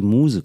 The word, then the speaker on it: Muse: Und zwar habe ich dann immer gesagt, wenn mich die Muße küsst, und es heißt es aber, wenn mich die Muse küsst.